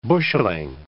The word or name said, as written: busheling